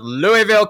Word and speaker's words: Louisville: I know you are in, as I said, I love pronouncing this word, Louisville, Kentucky. That's my best American accent, which isn't very good, I'm afraid.